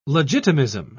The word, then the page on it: legitimism: British English